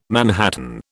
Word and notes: manhattan: British English

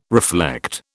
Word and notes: reflect: British English

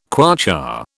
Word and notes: kwacha: British English

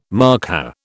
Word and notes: markhor: British English

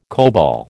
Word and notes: COBOL: American English

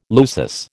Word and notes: lusus: American English

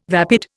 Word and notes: vapid: Indian

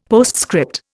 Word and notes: postscript: Indian

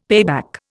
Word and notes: payback: Indian

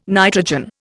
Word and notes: nitrogen: Indian

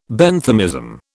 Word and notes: Benthamism: British English

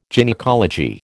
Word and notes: gynaecology: American English

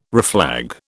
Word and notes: reflag: British English

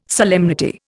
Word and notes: solemnity: Indian

